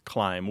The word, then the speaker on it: climb: And he talks about how interesting it is that in the 1300s, the B stopped being pronounced in words like climb. We used to say climb. Right? – Mm-hmm. Klimbin. – Klimbin, right. Klimbin, yeah. And same for bomb, B-O-M-B.